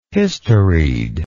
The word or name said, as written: historied